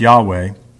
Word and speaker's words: Yahweh: It's four letters in the Hebrew text that we pronounce Yahweh, but the Jewish people would never say, ever.